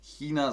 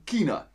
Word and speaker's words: China: Wörter mit ch werden meist mit K ausgesprochen, wie in Bayern auch, zum Beispiel nicht Chemie, sondern Chemie und nicht China, sondern China.